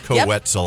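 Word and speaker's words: Koe Wetzel: Rhymes with pretzel. Co-Wetzel.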